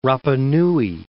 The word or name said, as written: Rapa Nui